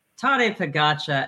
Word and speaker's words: Tadej Pogačar: And then Spectre's Tajay Sharp. How do you pronounce his name again? – Tadej Pogačar.